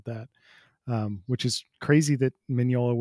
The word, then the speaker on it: Mignola: and Mike Mignola. I just mispronounced his name. – Mignola.